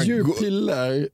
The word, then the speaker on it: Jupiler: För att säga, men så säger de, nej det är inte Jupiter, det är Jupiler.